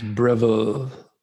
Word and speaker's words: Breville: So I think it's pronounced Breville. It's French.